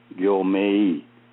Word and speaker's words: Gyomei: So he changed the spelling from M-E-I to M-A-Y so that it would be easier for people to say Gyomei when they see that. And that's just a kind of interesting history in terms of his Dharma name.